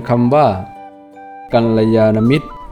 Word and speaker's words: กัลยาณมิตร: อ่านว่ากัลยาณมิตร